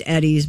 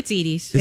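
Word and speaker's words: Edy's: It might be pronounced Eddie's, but whatever. – Is it Eddie's? – It's Edie's. – Is it? – Edie's ice cream. – Edie's.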